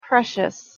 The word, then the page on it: precious: English